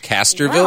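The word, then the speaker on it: Castroville: But he was talking about the garlic festival, and he said, correct me if I say this wrong, there's a Castleville artichoke festival? – I think he said Castorville.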